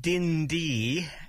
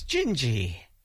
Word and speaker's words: Dindi: And of course, it's not Dindy as it looks. It's pronounced D-I-N-D-I, but the pronunciation is Gingy.